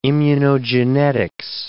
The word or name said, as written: immunogenetics